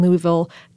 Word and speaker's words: Louisville: Well, the Kentucky Derby is a spectacle horse race, spectacular, conducted every year at the Churchill Downs Racetrack in Louisville, Kentucky. And I'm from Ohio and South Florida, so I can't say Louisville quite correctly. There's like an extra syllable of vowel in there that, can you say it for me one more time? – Louisville?